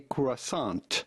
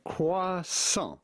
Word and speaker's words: croissant: Well, essentially, if you are able to pronounce the French R like the French do, just say croissant. Croissant. The T at the end of croissant is definitely silent, so do not say croissant, but rather croissant.